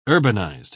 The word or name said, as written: urbanized